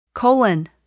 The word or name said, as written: colon